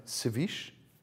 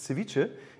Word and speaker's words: Ceviche: Manche sagen Ceviche, aber ich glaube, richtig heißt es Ceviche.